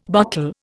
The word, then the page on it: bottle: Indian